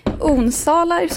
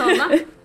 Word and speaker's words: Onsala: Tack, och vilket härligt vårväder ni har här i Onsala, så det uttalas. – Ja, precis, Onsala.